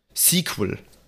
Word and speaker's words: SQL: Sequel. Äh, offizielle Aussprache.